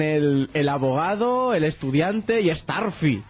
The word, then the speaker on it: Starfy: ser que la pronunciación oficial es Starfy. Lo cual tiene bastante más lógica. – Sí. Uy, sí, lógica de Curro Jiménez, por lo menos. – Dios, Staffy no se llama más Staffy.